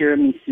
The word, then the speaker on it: tiramisu: For my kitchen, I think I would pick a tiramisu machine.